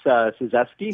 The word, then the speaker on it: Suzeski: That's definitely how it looks. It's Suzeski. – Suzeski. – Close. Americanized Polish.